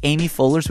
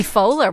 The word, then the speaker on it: Poehler: I think I respect Tina Fey, but I think Amy Poehler's would be more like... – Amy Poehler? Would you have to get her name right, first of all? – Sorry. – Hello, Mr. and Mrs. Poehler.